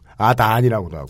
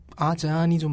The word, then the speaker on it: 아잔: 두 가지 표기를 하더군요. 아잔이라고도 하고, 아. 아잔이라고도 하고, 아단이라고도 하고. – 아잔이 좀 맞는 발음일 거예요.